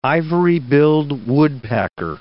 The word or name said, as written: ivory-billed woodpecker